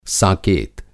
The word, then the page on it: साकेत: ساکیت